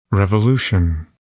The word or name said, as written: revolution